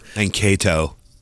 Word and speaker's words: Mankato: Mankato. Please pronounce it Mankato. like the city in Minnesota.